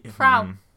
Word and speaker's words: Frau: And a woman referred to as Fra Trophy. Don't know. Fra Trophy, does that sound right? Is that a name in French? – Frau. – Frau, yeah, Frau. It's spelled like that.